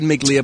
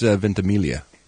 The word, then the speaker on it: Ventimiglia: Yeah, I think it's Ventimiglia. – Oh, is it? – I always said Miglia, but I believe the G is silent. I think.